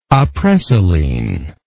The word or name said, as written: Apresoline